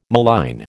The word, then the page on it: malign: American English